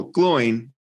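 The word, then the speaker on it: Glóin: Well, how'd you pronounce it? Gloin? – Gloin. Gloin. Gloin. Like one Gloin. Syllable, Gloin. And she's like, no, it's Gloin. And she explained why.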